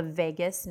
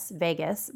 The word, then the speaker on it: vagus: So they're connected via a major communication pathway that's called the vagus nerve. So it sounds like V-E-G-A-S, vagus, but it's spelled V-A-G-U-S. V-E-G-A-S is what it sounds like, and it's spelled V-A-G-U-S.